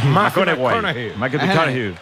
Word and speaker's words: McConaughey: ¿Matthew McConaughey? – Sí, sí, sí. Sí, sí. – Que hay que ir corrigiendo la pronunciación. – McConaughey.